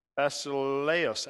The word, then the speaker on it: basileus: Basileus, actually, basileus is how you say it. It's not U E S, it's E U S.